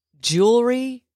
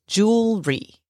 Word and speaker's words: jewelry: So jewelry. So I've always said jewelry, like a jewel, but it's supposed to be just jewelry, which makes it sound Southern. It's supposed to be just two syllables, jewelry.